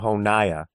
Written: Honijah

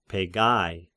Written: Pegai

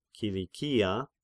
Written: Kilikia

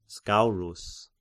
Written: Scaurus

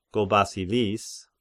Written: Gobasilis

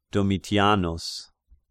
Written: Domitianus

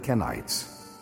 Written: Kenites

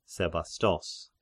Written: Sebastos

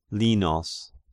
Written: Linos

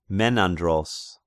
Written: Menandros

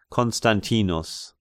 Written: Constantinus

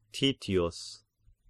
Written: Titius

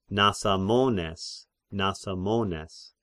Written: Nasamones

